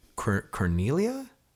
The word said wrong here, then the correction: Carnelian: Carnelian, and yes.